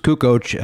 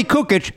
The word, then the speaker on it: Kukoc: Kukoc is the way that it is, but people would go, yeah, so I'm watching the game last night in Kukoc. Nope. You're talking to him. Hey, Tony Kukoc. It's pronounced Kukoc. Yeah, Kukoc. Like, no dumb Chicagoan would ever catch on to how to say it.